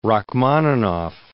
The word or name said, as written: Rachmaninoff